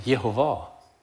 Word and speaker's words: Jehova: Also ich darf euch mit grosser Sicherheit, ich habe es wirklich hinter zum Führerzinn angeprüft, sagen, der Name ist zu Jesu Zeiten ausgesprochen worden, Jehova.